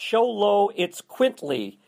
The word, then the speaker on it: Xoloitzcuintli: He is it's supposedly pronounced Sholo It's Quintly, which is XOLOITZCUINTLI.